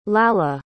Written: Lalla

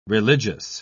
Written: religious

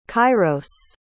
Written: kyros

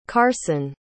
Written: carsen

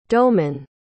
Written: doughmen

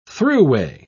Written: thruway

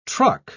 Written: truck

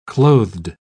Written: clothed

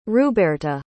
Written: ruberta

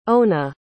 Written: ona

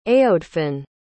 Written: aodhfin

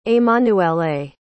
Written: emanuele

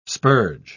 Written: spurge

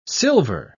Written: silver

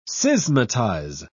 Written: schismatize

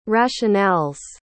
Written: rationale's

